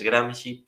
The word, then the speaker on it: Gramsci: Una última cosa, hablando de pronunciación, la forma correcta de pronunciar, como quien dice, es Gramsci, porque los italianos, la SC, lo utilizan como sustitución de la X, por eso también fascismo, ellos pronuncian fascismo.